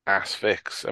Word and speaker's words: Asphyx: There's the correct pronunciation is Asphyx.